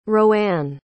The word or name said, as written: roanne